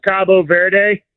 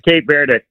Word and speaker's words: Cape Verde: Also, how do I say Cape Verde? How do I say that country? – Either way, you said it right. It's kind of like Turkey, Turkia. – Yeah. – In Spanish, it's Cabo Verde. – Cabo Verde. – Cabo San Lucas or whatever in Mexico. Cabo Verde. But okay. – The English pronunciation is Cape Verde.